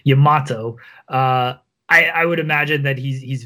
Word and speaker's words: Yamato: And considering that they're pairing him with, is it Yamato or Yamo? – How Yamato. Do I say it? Yamato, okay.